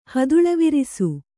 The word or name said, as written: ಹದುಳವಿರಿಸು